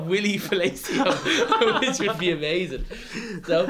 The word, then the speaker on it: Palacios: Ex-Wigan player, Wilson Palacios. You know, it's pronounced Palacio, okay? – Oh, very good.